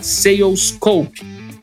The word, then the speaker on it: Salescope: Eu vou deixar aqui o link também para você já anotar e conferir na sequência adm.to barra salescope. E o Salescope se escreve com S-A-L-E-S-C-O-P-E.